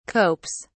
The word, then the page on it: copes: British English